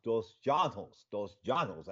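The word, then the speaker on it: dos Anjos: Dos Janos. That's the way he wants his name pronounced.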